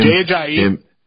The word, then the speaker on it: Ajayi: So, running back, you got Jay Ajayi, or I think that's how you pronounce his name. – Jay Ajayi.